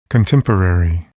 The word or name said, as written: contemporary